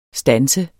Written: standse

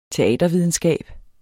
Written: teatervidenskab

